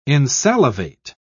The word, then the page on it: insalivate: British English